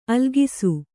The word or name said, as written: ಆಲೋಕಿಸು